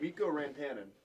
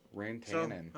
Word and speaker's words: Rantanen: And number one, Colorado, but with Mikko Rantanen. – Okay, he's Mikko Rantanen. Okay. – Rantanen.